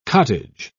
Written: cottage

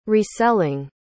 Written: reselling

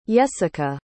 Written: yessica

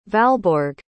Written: valborg

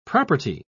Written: property

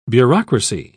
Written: bureaucracy

